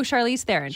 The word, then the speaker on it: Charlize Theron: Oh, Charlize Theron.